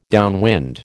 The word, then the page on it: downwind: American English